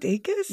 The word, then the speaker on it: Dacus: Is it Dacus? – Yeah, it's Dacus.